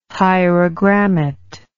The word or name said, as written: hierogrammat